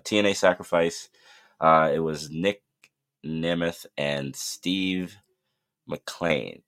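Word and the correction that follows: Maclin: Did I say that right? Macklin. Ooh, I messed his last name up.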